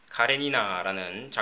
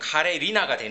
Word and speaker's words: 카레니나: 예, 이게 빨리 하다 보면 카레 리나가 되는데 카레 니나입니다.